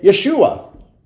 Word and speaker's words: Yeshua: In fact, in Hebrew, they say Yeshua. And in Greek, they say Yesu.